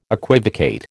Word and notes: equivocate: American English

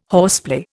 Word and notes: horseplay: Indian

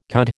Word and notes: cud: American English

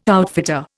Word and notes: outfitter: Indian

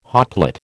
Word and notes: hotplate: American English

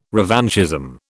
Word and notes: revanchism: British English

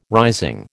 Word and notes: rising: American English

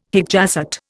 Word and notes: hic jacet: Indian

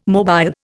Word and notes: mobile: Indian

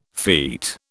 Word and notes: feat: British English

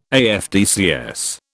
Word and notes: AFDCS: British English

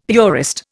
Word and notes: purist: Indian

